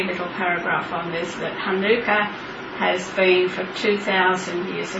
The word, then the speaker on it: Hanukkah: As Rosemary said, Hanukkah can be Hanukkah or Hanukkah or Chanukkah, as Anglicised say, but that's supposed to be pronounced, which I can't really do, with a very guttural H at the beginning of Hanukkah. – Hanukkah. – Hanukkah. Very, very strong guttural sound, which if you're in Israel, you hear lots of Sabra talking in that way.